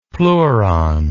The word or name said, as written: pleuron